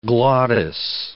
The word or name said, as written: glottis